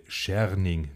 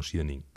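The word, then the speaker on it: Scherning: Ja, Entschuldigung geht raus an den guten Braunschweiger Trainer Daniel Schiering natürlich und nicht, wie von mir falsch ausgesprochen, Schiering.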